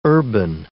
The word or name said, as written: urban